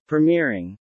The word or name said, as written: Premiering